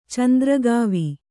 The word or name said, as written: ಚಂದ್ರಗಾವಿ